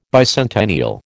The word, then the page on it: bicentennial: American English